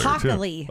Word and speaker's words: Hochuli: Boy, he explains it like a lawyer, Hockley. – Too. Oh, Hockley. Okay. – Yeah. People are calling like, that's not right. – Well, I appreciate the correction because it's spelled H-O-C-H-U-L-I.